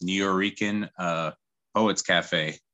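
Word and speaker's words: Nuyorican Poets Cafe: And you got to perform that milestone at places like the, I still never heard that name really pronounced, New Yorican Poets Cafe. – Yeah. Well, that's how it's pronounced.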